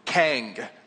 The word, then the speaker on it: King: Kang. Who lives in Kang? All right, got two. All right, they speak a different language. It's spelled King, but it's Kang, okay?